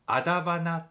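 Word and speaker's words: 徒花: まあ咲いても実を結ばない花のことをアダバナっていうのを知っているでしょうか。アダバナって読むことがあるんですよね。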